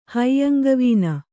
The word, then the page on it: ಹೈಯಂಗವೀನ: Kannada